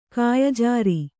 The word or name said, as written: ಕಾಯಜಾರಿ